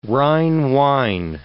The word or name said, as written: Rhine wine